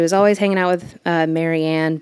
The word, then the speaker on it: Marie: And when they found this house, they decided to hire an assistant named, it's spelled Marie, but in interviews, Shania pronounces it Mary.